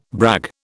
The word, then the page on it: brag: British English